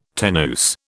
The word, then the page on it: tenuis: British English